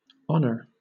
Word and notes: honour: British English

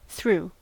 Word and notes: threw: American English

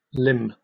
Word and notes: limn: British English

